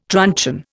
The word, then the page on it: truncheon: Indian